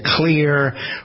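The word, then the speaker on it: Xlear: There's a system from a company called Clear. It isn't spelled with a C, it's spelled with an X. X-L-E-A-R.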